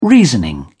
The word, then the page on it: reasoning: British English